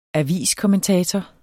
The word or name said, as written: aviskommentator